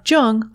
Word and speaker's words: Jung: You can also listen to this podcast on your Amazon Echo device simply by saying, Alexa, play Speaking of Jung on Apple Podcasts. Just be sure to pronounce Jung with a hard J.